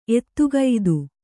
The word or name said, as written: ಎದೆಗತ್ತಿ